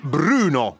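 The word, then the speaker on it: Brüno: E questo era un passaggio di Bruno, bisogna pronunciarlo così, con l'umlaut, come dicono i tedeschi, anzi anche nei titoli di testa la Universal si è messa appunto i puntini sulla U e diventa Universal.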